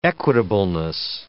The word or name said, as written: equitableness